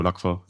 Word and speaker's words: Lacqua: Ich würde es nicht so betonen, einfach nur Lacqua.